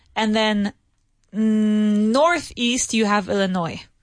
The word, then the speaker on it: Illinois: We do write it Illinois. So we spell it Illinois. All right. But we don't say Illinois. We say Illinois. No S sound. Illinois.